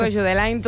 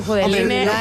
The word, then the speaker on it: Judeline: Yo le digo Jude Lain, tú Judeline. Tenemos gustos para todos y pronunciaciones para todos.